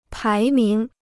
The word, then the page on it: 排名: pái míng